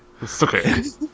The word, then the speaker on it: Sookie: Anytime anyone says, Sookie, can we use the HBO-approved True Blood pronunciation of Sookie? – Yeah. – Sookie? Sookie. – Sookie. All right, give me that. – So, like Cartman? It's like a Cartman voice? – Southern Cartman. Sookie.